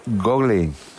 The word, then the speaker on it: Google: Google. – Ah, vale. Google, vale.